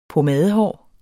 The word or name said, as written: pomadehår